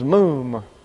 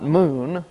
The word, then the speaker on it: moom: And the word translated defect is moom. Not moon. We're not mooning anything here. That's moom, pronounced M-O-O-M, moom.